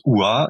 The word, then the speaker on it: Oohaah: Alors, ça s'écrit OUA. Ça se prononce OUA et ça s'écrit O-O-H-A-A-H.